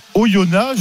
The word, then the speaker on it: Oyonnax: on dit bien Oyona Je suis on au. On prononce pas le X, Simon.